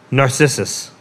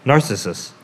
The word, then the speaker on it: Narcissus: Narcissus? – Oh, give me a crack at this. Narcissus. – Yeah. – Narcissus. – Narcissus. – There you go.